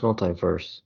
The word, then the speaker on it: MultiVersus: Multiversus. – It's pronounced multiverse. – Multiverses. – Oh, no. – The multi is multiverse, but it's spelled different.